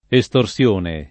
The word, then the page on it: estorsione: lingua italiana